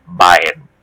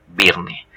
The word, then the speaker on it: Byrne: parece ser que se pronuncia Bayern y no Birne, que me he tenido que ver unos cuantos vídeos en YouTube en inglés para intentar corroborarlo.